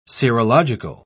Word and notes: serological: British English

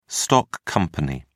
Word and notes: stock company: British English

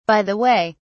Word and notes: by the way: British English

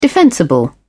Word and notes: defensible: British English

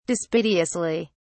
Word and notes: dispiteously: British English

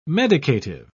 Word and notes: medicative: British English

